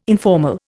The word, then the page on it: informal: Indian